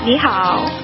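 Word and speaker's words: Ni hao (你好): Ni hao. – Ni hao. Did I say that right? – Ni hao.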